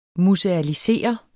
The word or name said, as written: musealisere